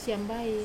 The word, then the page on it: siambai: Wamesa